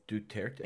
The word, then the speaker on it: Duterte: So it's Duterte, right? I can never figure out how to say this guy's Duterte. – It's Duterte, I think. – That sounds right. – In any case, Duterte.